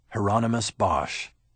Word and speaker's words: Hieronymus Bosch: He tried to teach me to say the painter's name. Hieronymus Bosch. Rhymes with anonymous, he told me.